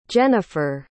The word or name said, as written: jenifer